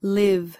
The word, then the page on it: live: American English